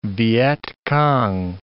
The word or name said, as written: Vietcong